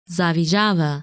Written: Zavijava

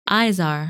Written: Izar

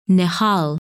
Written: Nihal

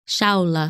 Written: Shaula

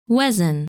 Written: Wesen

